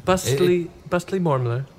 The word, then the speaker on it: Bustly Mormler: All right, we are back with Polo Tatums and Busty Mormon. – It's Mormler. Bustly Mormler.